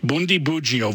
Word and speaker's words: Bundibugyo: Bundibugyo virus.